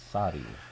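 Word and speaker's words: Sahti: And is my pronunciation right? – Maybe you better spell it. – It's a S-A-H-T-I, okay. Sati. – So, yes, just one, Sati.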